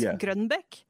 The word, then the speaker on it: Grønbekk: Actually, Torunn, I don't actually know how to say your last name. How about that? – See, that was perfect. No one can... There's no English way to say my name. It's Grønbekk.